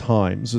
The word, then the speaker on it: Jaimes: Or Himes, is that how you pronounce J-A-I-M-E-S. – Yes. – I think it's Himes is how he pronounces it.